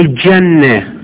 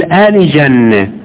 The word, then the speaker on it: الجنة: بعض الناس عندهم انحراف في بعض الحروف، مثل في الجيم عندك كثير من غيرنا ينطقوا الجيم شمسية، يقولوا الجنة جواد، لاحظ الجنة مثل الشمس، مع أن الجيم قمرية المفروض يقول آل جنة، آل جنة مو الجنة، آل جواد مو الجواد.